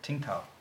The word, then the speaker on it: Qingdao: I think it's Qingdao. – Qingdao.